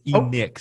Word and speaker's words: Enoch: Because my name is pronounced a little differently. It's Enick. So it's kind of like Nick with the E in front.